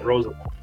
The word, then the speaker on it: Rozeveld: Yep, Rozeveld.